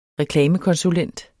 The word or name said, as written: Reklamekonsulent